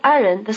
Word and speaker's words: 爱人: Notice that in the word 爱人, the second syllable has the neutral tone.